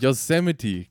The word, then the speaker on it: Yosemite: Wahrscheinlich Yosemite, wie der Park oder was? Yosemite, genau. Yosemite, es ist der Park.